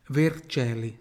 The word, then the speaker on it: Vercelli: Vercelli. Por que que é Vercelli? Porque CE na língua italiana tem som de CE, mas BA CE, como falam os gaúchos.